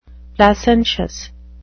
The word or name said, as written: placentious